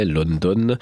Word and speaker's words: London: C'est comme ça que ça se prononce en anglais, London.